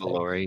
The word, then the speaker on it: Revolori: I don't know how to say his last Revolori.